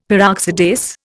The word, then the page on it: Peroxidase: Indian